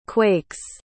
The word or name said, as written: quakes